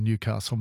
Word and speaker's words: Newcastle: But, yeah, I'm standing hard and fast on Newcastle, mate. There is no Newey. If you phone into this program, if you're on this program, it's Newcastle. It's not that hard. It's three syllables.